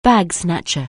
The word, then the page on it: bag-snatcher: anglais